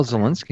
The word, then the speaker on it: Zielinski: Yeah, it is Zielinski. – Cool. So you're our age. I mean, he's 1980. I'm 81. Rick is 80. Are you a fan of Tommy Boy, the movie? – Well, Zielinski is my name there.